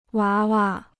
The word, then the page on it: 娃娃: wá wa